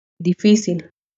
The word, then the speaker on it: difícil: Difícil. Note how the emphasis is in the second syllable.